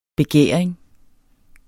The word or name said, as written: begæring